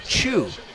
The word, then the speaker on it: Tew: And it's spelled T-E-W. – Yes, it is spelled T-E-W, but it is pronounced Chu.